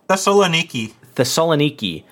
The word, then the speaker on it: Thessaloniki: Thessaloniki had put together a zero or microgravity friolator.